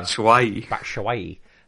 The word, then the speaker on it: Batshuayi: Batshuayi.